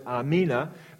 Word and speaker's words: Amen: They pronounce it Amina, but it's the very same word.